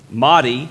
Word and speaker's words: Mahdi: Mahdi, did I say that right? – Yeah.